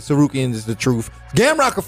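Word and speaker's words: Tsarukyan: Main event of the evening, Arman Tarkanian. How you say it? Tarkanian? – Sarukian. – That's right.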